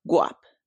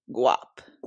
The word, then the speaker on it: Guap: Guap. – What? – Guap. – Guap? – Guap. – Like, why you say it like that? Guap. – It has to have swag. – Wait, like G-U-A-P.